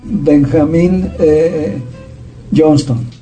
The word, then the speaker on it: Benjamin Johnston: lo vamos a pronunciar en inglés, Benjamin Johnston.